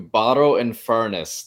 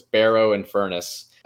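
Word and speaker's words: Barrow-in-Furness: Following his marriage, he and his wife moved to Barrow and Furness. That's how I like to pronounce it, but it's Barrow and Furness.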